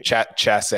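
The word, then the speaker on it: Chassart: Adrian Dumont de it's spelled Chass Art, but it's probably said in a much more Violet. Regal way than I can say. – Chasse or something. – Chasse, yes. Yes.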